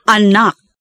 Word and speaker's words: anak: The four types of stresses are A. End stress, where the last syllable of the word receives a stronger emphasis than the others. – Anak. A-nak.